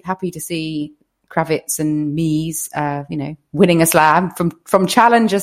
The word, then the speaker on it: Mies: It's Andreas Mies, not Miles.